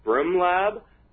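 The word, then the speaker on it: Brmlab: But from there, we continued on, and we got a really, really fascinating tour at Brum Lab. It's spelled B-R-M Lab.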